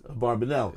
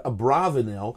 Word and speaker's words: Barbanel: No, Barbanel is a very musical name. – Like yes, it's Barbanel. – Yeah. – And of course, now you could pronounce it Abravanel, yeah, which yeah. ruins the whole thing.